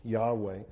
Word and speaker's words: Yahweh: But it's commonly accepted today a better way of pronouncing it would be Yahweh.